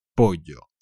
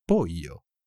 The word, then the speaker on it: pollo: En español, la L será como la L de jeans, pollo. In some countries, it would more sound like a I, pollo. And even in Argentina, they will make sh out of it.